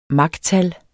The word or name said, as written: machtal